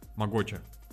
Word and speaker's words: Могоча: На второе О ударение. Могоча.